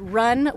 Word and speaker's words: Ryun: R-Y-U-N. – Yes, it certainly is. And I always say it's run with a Y, R-Y-U-N.